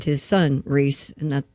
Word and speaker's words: Rhys: Does he pronounces it Rhys? – Rhys. – Does he? I don't know. – Oh, it is Rhys.